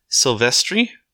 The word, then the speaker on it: Silvestri: So I did not find the character you did, Shane Black, but the composer for this movie was Alan Silvestri. I might have said that last name wrong. I probably did. – Nope, you got it. Nope, that's how you pronounce it.